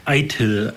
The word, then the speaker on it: ITIL: Also ITIL wird das meistens ausgesprochen.